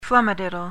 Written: flummadiddle

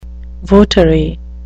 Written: votary

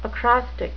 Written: acrostic

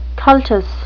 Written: cultus

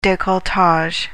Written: decolletage

